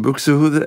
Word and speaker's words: Buxtehude: En ik heb die LP van Alfred Deller zingt op de A-kant muziek van Buxtehude. Ik weet niet hoe je het moet uitspreken. – Ja, dat is helemaal juist. – Buxtehude of Buxtehude.